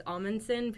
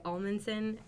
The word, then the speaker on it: Amundson: And I guess if you want to know the reason of the name, it's because people mispronounce my last name, which is Amundson. People say Amundson,